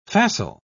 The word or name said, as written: facile